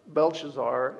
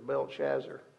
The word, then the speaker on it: Belshazzar: Belshazzar is the correct way to pronounce it, but the way I always heard it was Belshazzar. So if you want to say it that way, I'm okay with it.